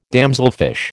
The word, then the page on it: damselfish: American English